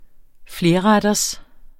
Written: flerretters

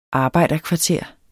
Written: arbejderkvarteret

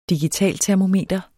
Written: digitaltermometer

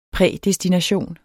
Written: prædestination

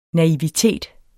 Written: NAIVITETEN

